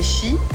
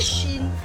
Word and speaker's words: Shein: Donc SheInside, ça fait mais SheIn. On dit Shein, pas Shein.